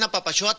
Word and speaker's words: Apapaxoa: Entonces, la invitación abierta a Papaxoa, se escribe con X, Juan, ¿eh? Claro. A Papaxoa, se pronuncia como si fueras de Chihuahua, pero es a Papaxoa.